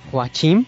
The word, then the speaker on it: Joachim: Bueno, Joaquín es en español, es Joachim. Es que en Alemania es igual, o sea, se pronuncia tal cual es el nombre.